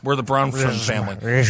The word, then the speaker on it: Bronfman: It's literally Bronfman. And I'll tell you what it is. It's that F. They stick right in the middle. Bronman? Got it. Bronfman.